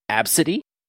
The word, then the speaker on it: ABCDE: Do you remember, this one family was in the news not too long ago because they had a child whose name was ABCDE, pronounced Absidy.